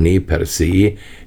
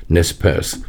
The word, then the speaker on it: Nez Perce: Die Nez Perce, richtig eigentlich gesprochen Nez Perce, die durchbohrten Nasen, amerikanisch aber halt dann immer Nez Perce ausgesprochen,